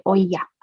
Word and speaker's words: olla: Café de olla. – Olla. – O-L-L-A.